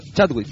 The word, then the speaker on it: Chadwick: Dentro de ellos salió el perro de Chadwick, de, no sé si lo pronuncié bien el apellido. – Yo no, Chadwick, sí.